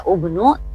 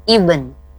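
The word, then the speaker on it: ibn (ابن): Bukan seperti teori ya, huruf ketiganya berharokat doma dibaca ubnu, tapi dibacanya ibn, ibn, ya, atau ibnu dibaca terusnya ya.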